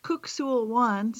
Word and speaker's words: Kuk Sool Won: It's actually pronounced Kuk-Sul Wan for anybody who's out there who practices this. That it looks like cuck, but it's kook.